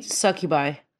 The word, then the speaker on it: succubi: Sweet succubi.